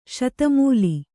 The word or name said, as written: ಸದಮಲ